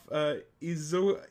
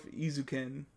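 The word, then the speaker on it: Eizouken: And it's going to be Keep Your Hands Off Izuken. That's how you say it. Keep Your Hands Off Izuken.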